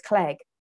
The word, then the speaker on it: Clegg: So, like, you know, Clegg was like egg, smeg, dreg, keg, peg, you name it.